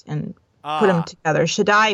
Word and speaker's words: Shaddai: By the way, if you see Shaddai, that's pronounced Shaddai. – Shaddai.